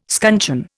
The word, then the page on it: scuncheon: Indian